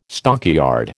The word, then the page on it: stockyard: American English